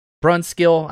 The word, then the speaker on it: Brunskill: Daniel Bruinskill Brunskill. I know I say it wrong.